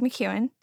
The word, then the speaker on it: McKeown: And his name is Greg McKeown.